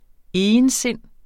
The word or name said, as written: egensind